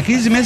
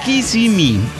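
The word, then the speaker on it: Kissimmee: Pirata, Kisme se pronuncia Kisimi. – Kisimi. – Kisimi. O con acento en la segunda I.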